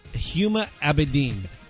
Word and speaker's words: Huma Abedin: Did you mispronounce that? – No, it's Huma Abedin.